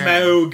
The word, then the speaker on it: Smaug: A lot of people on the create 2K Smaug. Game. Say it. You gotta say it correctly. – Smaug. – It's not like a very pretentious fart.